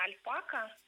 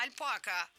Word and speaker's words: альпака: И, конечно, вариант альпака какой-нибудь, да, получается, нам был, ну, как-то неестественен. Нам хотелось, чтобы ударение было в середине корня.